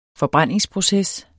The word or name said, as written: forbrændingsproces